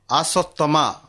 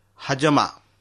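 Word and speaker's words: asthma: এহাকু ভুলরে অনেক আসস্তমা বলি কহন্তি, কিন্তু এহার প্রকৃত উচ্চারণ হাজমা।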